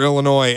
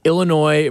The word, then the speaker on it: Illinois: I also saw, too, there was a great tweet out that Illinois versus Arkansas in Des Moines has to hold some sort of NCAA record between teams and location with silent S's. Arkansas, Illinois, Des Moines.